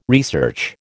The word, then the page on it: research: American English